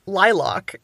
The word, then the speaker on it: lilac: And she looked at me with horror, because it turns out here it's pronounced lilac. – I would say lilac, which is yet different again. – Oh, interesting. Like lilac, like with more of an O sound? – Yeah, like a lock and key lilac.